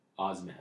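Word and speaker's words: Osimhen: Osmine, I believe it is. – Yep. – Let me confirm 100%. Osman, I believe it's pronounced.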